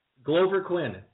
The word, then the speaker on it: Glover: Grover Quinn or Glover. Or how do you say his name? Anyway, it doesn't matter. – Sure, Glover. – Glover Quinn, that's awesome.